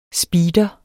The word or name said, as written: SPEEDEREN